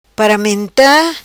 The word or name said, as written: Paramenta